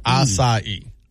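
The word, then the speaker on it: açaí: I believe it. No, it's acai. – Okay. Acai.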